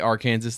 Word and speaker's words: Arkansas: I think they have a Bigfoot festival held each year near the Arkansas border. – The Arkansas, that's called Arkansas. – I'm calling it Arkansas because that's how it's spelled, okay? – Arkansas.